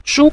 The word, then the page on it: shu: shu5